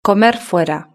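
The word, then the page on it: comer fuera: Spanish (Spain)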